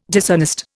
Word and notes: dishonest: Indian

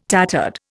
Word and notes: tattered: Indian

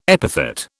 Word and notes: epithet: British English

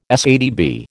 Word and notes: SATB: American English

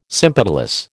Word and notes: sympetalous: American English

